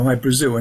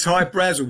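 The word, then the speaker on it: Hy Brasil: High Brazil, people pronounce it High Brazil, and it's Brazil, okay. – Yeah. But it's High Brazel because that's how the Irish would pronounce it, because it's an it's an island that's off the southwest coast of Ireland.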